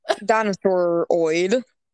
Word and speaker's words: dinosauroid: There is a theory called the dinosauroid theory. I can't even, I didn't even try to pronounce that before just now. just replace the word human and humanoid with dinosaur. – Dinosauroid.